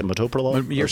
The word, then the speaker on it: metoprolol: There's 14.8 million people that take metoprolol. – Yes, yeah. – How do you say it? Metoprolol? – Saying it right. Wow. Yeah, metoprolol.